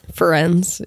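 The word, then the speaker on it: Firenze: and he runs into this cloaked figure and is saved by Ferenczine, I believe is how you pronounce his name. – Ferenczine, yeah.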